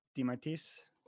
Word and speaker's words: DeMartiis: Donato DeMartiis. I said that right, DeMartiis? – You said it right.